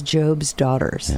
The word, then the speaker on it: Job's Daughters: The name is Job's Daughters, yeah. – Like the Bible book of Job with the long O.